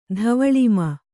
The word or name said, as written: ಧವಳಿಮ